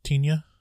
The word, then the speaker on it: Tinja: I believe it's pronounced Tinja, not the pronunciation.